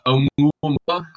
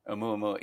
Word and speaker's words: Oumuamua: I love thinking about the Oumuamua. I'm probably pronouncing that wrong, but the you pronounced that's correctly. It is correct, assuming I'm pronouncing it correctly, and I assume I am. Oumuamua, yeah.